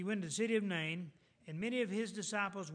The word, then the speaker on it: Nain: a city called Nain, N-A-I-N, Nain. That city's still there today, but it's called N-E-I-N, Nain, I guess how you pronounce that.